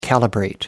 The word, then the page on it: calibrate: British English